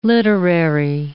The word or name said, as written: literary